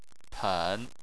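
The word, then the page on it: pen3: tone 3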